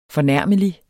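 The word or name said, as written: FORNÆRMELIG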